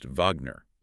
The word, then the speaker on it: Wagner: Ah, like how the composer Wagner is spelled with a W but pronounced Wagner.